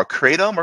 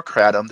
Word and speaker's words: kratom: And what I mean by OTC opioids are the two big ones we're seeing, especially in the poison center world, are kratom or kratom, depending on how you want to pronounce it.